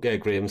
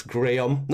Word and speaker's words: Graham: The problem to me is probably what Graham said as well. And by the way, for any Americans listening, it's Graham, not Graham. No, it's Graham.